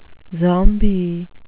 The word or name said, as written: zombie